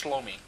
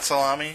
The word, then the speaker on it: Shlomi: Pronounced Shlomi. – Shlomi? – Yeah, it rhymes with blow me. I mean, dude! – Vince Shlomi. Sure, Shlomi. Not salami, Shlomi.